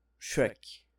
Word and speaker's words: Shrek: Shrek.